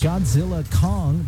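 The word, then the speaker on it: Godzilla x Kong: At the multiplexes, Godzilla Kong, the new empire. Yes, the X is silent.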